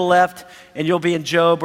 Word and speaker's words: Job: Job's in the Old Testament. A lot of people think it's a job because it's spelled the same way, right? But it's Job.